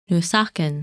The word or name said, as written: Nusakan